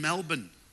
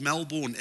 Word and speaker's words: Melbourne: I'll get it right, mate. It's Melbourne. Said, but it's spelt M-E-L-B-O-U-R-N-E, so in my South African, it says Melbourne. Maybe, mate. But here, it's pronounced Melbourne.